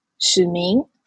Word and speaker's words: Su Beng: And like what I found interesting is that because I when I work with one old historian in Taiwan and whose name is Shih Ming or Su Beng. Su Beng is more like when I pronounce his name in like English alphabet.